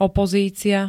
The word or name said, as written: opozícia